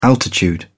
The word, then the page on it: altitude: British English